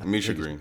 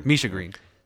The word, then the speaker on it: Misha Green: Misha Green. Is it Misha Green or Mia Green? – Misha Green. – Misha Green. Sorry.